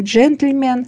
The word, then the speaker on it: джентльмен: Вот, будем внимательны, не будем говорить «джентльмен», будем говорить «джентльмен», потому что там этого звука «э» не должно быть.